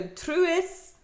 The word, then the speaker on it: Truus: Okay, so Truus, which is, I like to say her name, and I looked it up, and that's how you pronounce it.